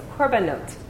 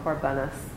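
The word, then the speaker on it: Korbanot (קרבנות): Korbanot, plural, or Korbanos, depending. You might have heard of it as Korbanos or Korbanot, depending on how you have heard words pronounced in Ashkenazic or Sephardic.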